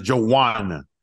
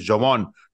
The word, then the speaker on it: Javon: Entonces hay veces que, por ejemplo, Jovan Kearse, no, su pronunciación es Joanna. Y dices tú, güey, se escribe J-A-V-O-N porque es Jovan. No, es Joan.